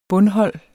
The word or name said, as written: bundhold